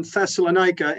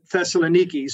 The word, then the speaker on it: Thessaloniki: So Paul establishes the church in Thessalonica, and modern Greece, they pronounce it Thessaloniki.